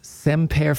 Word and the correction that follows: Siempre: Siempre Familia.